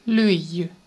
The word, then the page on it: lyijy: /ˈlyi.jːy/; /ˈlyijy/